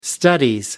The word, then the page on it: studies: British English